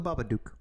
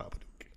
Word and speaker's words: Babadook: You, I had fun with this one, are the Babadook. – The Babadook? – Mostly because I want to see you in a silly top hat. – The Babadook. – The Babadook.